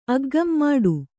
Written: ಅಗ್ಗಂ ಮಾಡು

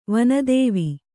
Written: ವನದೇವಿ

